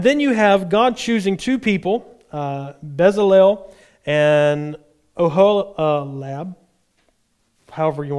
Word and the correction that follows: Oholiab: Z, how do you say that? Oholiab. There we go.